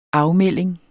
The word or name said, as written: afmelding